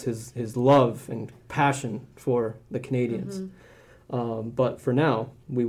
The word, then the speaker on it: Canadiens: The Canadiens. – That's correct. Okay. – Objection. She didn't pronounce it right. – The Canadiens.